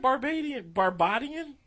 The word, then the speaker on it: Barbadian: Nigerian, Barbadian. Is that like being barbarian? – That's how you say it. Barbadian. – I don't know. – Barbados? – Oh, no. He's from Barbados, but they call him Barbadian.